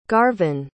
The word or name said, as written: garvan